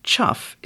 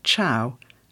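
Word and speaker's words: chough: Finally, now pronounced chuff, it would once have been pronounced chow, alluding to the bird's call.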